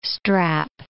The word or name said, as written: strap